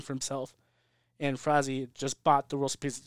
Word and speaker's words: Frazee: But so what ends up happening is a guy named Harry Fra- Frazee. Frazee. I don't say his name. His last name's like a freeze, like a Harry.